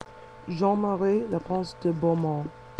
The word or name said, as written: Jeanne Marie Leprince de Beaumont